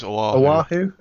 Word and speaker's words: Oahu: in particular, Ohio? – I think it's Oahu.